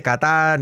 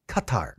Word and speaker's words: Qatar: No se dice Qatar ni Qatar, it's Qatar.